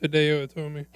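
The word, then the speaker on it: Hideo Itami: I believe it's pronounced Hideo Itomi.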